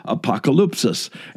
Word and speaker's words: apokalypsis: If I were to pronounce it even in Greek, it sounds like this, apokalypsis. And if you recognize that, we are very accustomed to hearing the English word apocalypse.